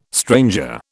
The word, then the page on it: stranger: British English